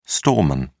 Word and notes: storeman: British English

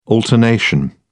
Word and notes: alternation: British English

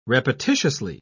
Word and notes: repetitiously: British English

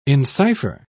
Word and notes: encipher: British English

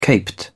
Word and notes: caped: British English

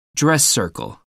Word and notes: dress circle: American English

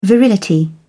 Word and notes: virility: British English

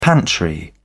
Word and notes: pantry: British English

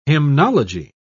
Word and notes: hymnology: British English

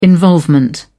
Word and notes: involvement: British English

